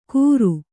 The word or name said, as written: ಗುರು